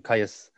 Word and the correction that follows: Kiyosaki: Kiyosaki, I think. – Kiyosaki yeah.